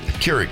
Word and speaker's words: Keurig: Johnny brought in a coffee maker because Krieg. – Krieg. – Keurig. – Keurig. Keurig. – Yeah. – You didn't even know how to say it.